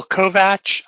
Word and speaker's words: Kovach: My name is Bill Kovach. I am with the Coastal Empire Montessori Charter School. Last name is spelled K-O-V-A-C-H.